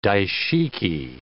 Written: daishiki

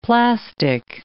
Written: plastic

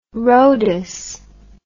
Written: Rhodus

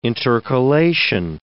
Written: intercalation

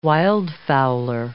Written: wildfowler

